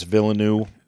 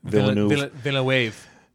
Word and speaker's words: Villeneuve: This movie, I read the article where the actor, I can never pronounce his name, Denis Villeneuve. – Villeneuve. – Yeah. – I think that's how they say it. I think it's Villeneuve.